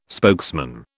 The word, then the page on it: spokesman: British English